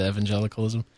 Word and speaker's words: evangelicalism: Notice how I said evangelicalism. – That's right. You said it right, brother.